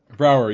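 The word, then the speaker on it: Braugher: Brower.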